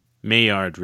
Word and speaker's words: Maillard: It's called the Maillard effect. – Maillard reaction. – Maillard. I can't say it.